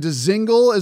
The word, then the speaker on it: Dzingel: I was able to speak with Ryan Dzingel, as I learned it's pronounced, after the game last night.